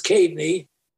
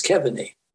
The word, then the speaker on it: Keaveney: They pronounce that here, I guess, Keveney. K-E-A-V-E-N-E-Y. – Okay. – It's Caveney, and in this country, it's Keveney.